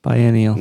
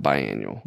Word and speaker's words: biennial: Biennial. Not biannual. Yeah. yeah biennial. Okay. Yeah, with an E.